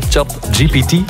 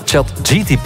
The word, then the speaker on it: ChatGPT: Nu heb ik het juist uitgesproken, hè? ChatGPT. – Uiteraard. – Ik zeg altijd ChatGTP. Ik weet niet hoe dat eigenlijk komt.